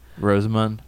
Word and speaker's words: Rosamund: Rosamund? – Yes.